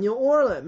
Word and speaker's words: New Orleans: 还有就是美国特别著名的一个州的一个城市叫新奥尔良，叫 New Orleans，美国人讲 New Orleans，没有 S 不能发音，包括像伊利诺伊州后面那个 S 也是不能发音。所以法语当中一个特别重要的特点就是最后的一个字母到两个字母它是不发音的。